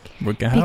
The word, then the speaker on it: Rutger Hauer: I'm gonna say Rutger Hauer. Is that how you say his name? – Rutger Hauer.